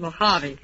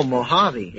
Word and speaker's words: Mojave: Pardon my cuticle, but that's pronounced Mojave. – Oh, Mojave. – Yeah, Mojave.